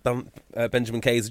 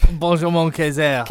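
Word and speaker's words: Benjamin Kayser: But I just couldn't help feel that I really wanted Benjamin Kaiser just to win one. – Sorry, say his name properly. – Sorry, Benjamin. – Benjamin Kaiser. – Kaiser.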